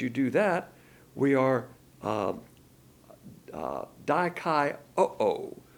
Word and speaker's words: dikaioo: The word justified here is dikaioo, D-I-K-A-I-O-O. In the Greek, when you have two O's on the end of the word, you pronounce it O-O. So dikaioo.